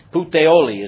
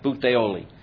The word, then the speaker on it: Puteoli: I looked up how you said this in Italian. I'm not an Italian, but Puteoli is how you say it. Puteoli. Or, I know it's Puteoli. That's how they say it, the accent.